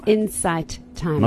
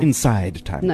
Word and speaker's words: Insight Timer: Insight Timer. Not Inside Timer.